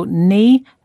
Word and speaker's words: knee: We don't usually say the K in words that begin with K-N. For example, knee, the joint between your upper and lower leg, and knife, the tool we use to cut things. So knee and knife start with silent K.